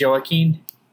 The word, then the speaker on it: Joaquin: It's pronounced Joaquin.